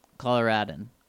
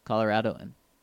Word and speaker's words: Coloradoan: Coloradan. Coloradoan.